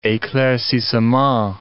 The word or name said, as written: eclaircissements